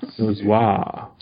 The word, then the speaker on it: Dozois: Dozois.